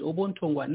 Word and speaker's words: Ubuntungwa: I know I may have butchered the pronunciation, but it's Ubuntungwa.